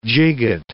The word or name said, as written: gigot